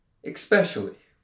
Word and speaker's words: especially: This week's episode is sponsored by the word especially. Especially. Where'd you get the X from, dude? There's not an X in there.